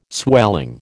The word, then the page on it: swelling: American English